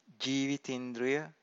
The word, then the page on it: jivitindriya: Pali